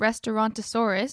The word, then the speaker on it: Restaurantosaurus: I had an interesting one at the Creature Comforts and Restaurantosaurus.